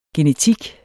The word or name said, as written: genetik